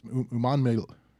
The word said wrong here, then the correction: Umanmielen: Do we know how to say this? I had it pulled up a minute ago. Here we go. Umanmielen, Princely Umanmielen, who recovered that fumble in the end zone.